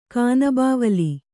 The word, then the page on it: ಕಾನಬಾವಲಿ: kānabāvali